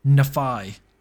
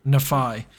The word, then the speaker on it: Nephi: But what I would say is the R, the final R in NFR, in the Egyptian form was pronounced as an I or a Y sound. So NFI or NFY, Nephi. – Nephi. – And if you have a look at the Hebrew, the name of Nephi is spelled N-P-Y, Nephi. But the P is the equivalent to an F, so Nephi.